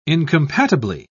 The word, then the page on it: incompatibly: British English